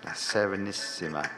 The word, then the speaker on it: La Serenissima: Yes, Einklein Night music, but I don't even know, it's called, uh, I can never pronounce it, La Serenade Anissima. La Serenissima, which I should be able to pronounce.